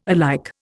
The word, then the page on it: alike: Indian